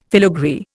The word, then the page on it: filagree: Indian